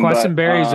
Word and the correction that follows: Queensberry: We mispronounced his name the entire time, and it was not a. We learned a lot about Questenberry in one episode. – Queensberry.